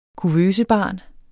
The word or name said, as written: kuvøsebarn